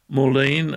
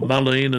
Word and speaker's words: Mullein: In this particular case, the herb that you are taking, Moulin or Mullein, as some pronounce it, M-U-L-L-E-I-N, a well-known